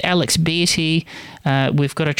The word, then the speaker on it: Beattie: Pretty sure that's how he told me to pronounce it, Beatty. But anyways, it's spelt B-E-A-T-T-I-E.